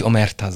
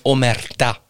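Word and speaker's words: omertà: Bitte nicht Omerta sagen. Ich weiß, ihr habt das sicher in irgendeinem Film gehört, Omerta, Omerta oder so. Nein, Omerta. – Genau. – Das wäre die richtige danke. Betonung.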